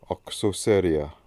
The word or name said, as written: Oksoseria